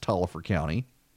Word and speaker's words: Taliaferro: Taliaferro County, spelled Taliaferro, but everyone assures me it's pronounced Taliaferro County.